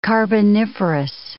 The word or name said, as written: carboniferous